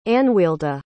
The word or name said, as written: anwealda